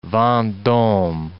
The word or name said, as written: Vendome